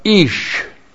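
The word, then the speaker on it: Ish (איש): And then we have ish. Aleph. Long I-S-H, Ish.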